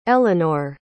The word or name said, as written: elynor